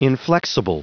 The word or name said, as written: inflexible